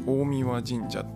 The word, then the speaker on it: 大神神社: ろご案内いただきましたでその中で、えっと、お酒の神様が祀られている大神神社っていうところに連れて行っていただきましたで大神神社って、えー、大きいに神って書いて大神神社って読みま